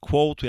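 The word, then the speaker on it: Claude: I think it's pronounced Quote. We actually discussed it before the... – Yeah. – Yeah. I think it's Quote because it's French. Never mind.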